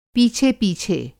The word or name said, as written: पीछे-पीछे